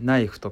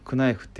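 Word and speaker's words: knife: ナイフとかもうほんと初めてだったら絶対 K は発音して「クナイト」とか「クナイフ」って呼んじゃう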